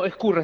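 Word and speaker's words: Ezcurra: Ascurra. – No, Ascurra, está bien, Ascurra. Está bien como lo pronunciaste.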